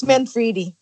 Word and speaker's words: Manfredi: It's Manfredi.